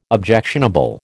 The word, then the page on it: objectionable: American English